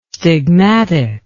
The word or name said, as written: stigmatic